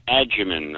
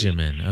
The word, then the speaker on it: Agyeman: It's Freema Agyeman, the actress who plays Martha Jones. – Agyeman, okay. – It's a soft G.